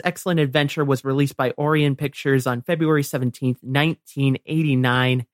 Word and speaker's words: Orion: I believe that's pronounced Orion. – Oh, is it Orion? – Yes. – What did I say? I don't even know what Orion. – I said. Oh, Orion. Okay.